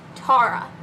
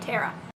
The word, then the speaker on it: Tara: It is pronounced Tara. And no, we did not have to go back and re-record this multiple times because I kept saying Tara.